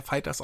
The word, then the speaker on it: FighterZ: aber es wird ja Fighters ausgesprochen, habe ich gelernt genau.